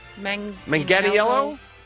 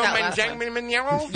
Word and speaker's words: Manganiello: Is it a hard G? A soft G? – Manganiello. – I think that's it. I think that's it right there. – Joe Manganiello. – Yep, that's nailed it.